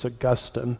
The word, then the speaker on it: Augustine: And here I'm using influence in a negative, not necessarily in a positive, a man named Augustine, or I've been corrected on my pronunciation, I guess it's Augustine. It depends on which syllable gets the emphasis, I guess.